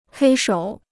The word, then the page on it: 黑手: hēi shǒu